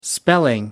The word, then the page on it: spelling: American English